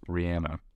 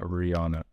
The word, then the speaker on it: Rihanna: Okay, Rihanna went on record in 2010 to clarify that her name is actually pronounced Rihanna. And not Rihanna.